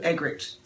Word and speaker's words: Eggerichs: Eggerichs.